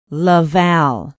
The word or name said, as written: la valle